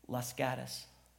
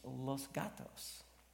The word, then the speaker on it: Los Gatos: And if you live there, you call that little bedroom community Las Gatas. Everybody, I don't care who you were, you call it Las Gatas. Well, if you pronounce it correctly, in particular, it's Spanish, it's Los Gatos, right?